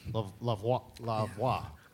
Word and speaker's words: La Voix: La voix. – Yeah. – Did I say that right? – Yeah.